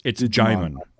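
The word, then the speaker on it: Djimon: Is it Jimon Hansu? We don't pronounce the D, right? – Or right, it's Jimon. It's Jimon Hansu.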